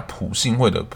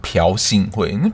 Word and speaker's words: 朴信惠: 那我就是另外一个分享个小知识了，就是在输入输入法的时候，你打朴信惠的朴，其实你打朴你会找不到，就是我们在电视上或是文章上看到的那个字，所以你其实要打瓢，就是一瓢饮的那个瓢虫的瓢，你才找得到这个字。只是在发音上，你真的不会念，就是朴信惠，那朴信惠到底是谁啊？